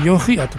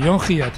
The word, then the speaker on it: Hiatt: John, ya sabéis cómo se escribe, y Hiatt es H-I-A-T-T, que yo dudaba de llamarle Haya, John Hiatt o John Hiatt, pero él me ha dicho que John Hiatt, pues John Hiatt.